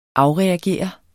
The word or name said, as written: afreagere